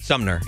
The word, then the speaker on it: Sumner: So this girl, Sumner Stroh, am I saying her name right? – Sumner, yeah.